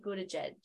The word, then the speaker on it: Buttigieg: He's closing it and he's handing it to Pete Buttigieg. Did I say it right? – Yeah.